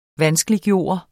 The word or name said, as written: VANSKELIGGJORT